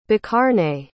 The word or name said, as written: bakarne